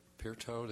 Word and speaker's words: Pirto: Mr. Pirto, did I pronounce that correctly? – Perfectly, just like they would say it in Finland.